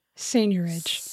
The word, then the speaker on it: seigniorage: Seigniorage.